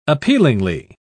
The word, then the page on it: appealingly: British English